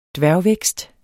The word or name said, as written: dværgvækst